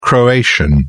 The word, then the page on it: croatian: British English